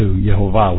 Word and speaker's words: Yehovah: And I switch that to Yehovah, which is the closest thing as I can find to the actual pronunciation.